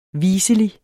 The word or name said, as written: viselig